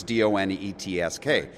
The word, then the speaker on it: Donetsk: It's Donetsk.